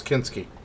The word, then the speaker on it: Kinski: Yeah, Klaus Kinski.